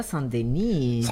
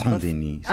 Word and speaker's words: Saint-Denis: Qué bien pronunciado Saint-Denis. – Saint-Denis. – Ah, perfecto.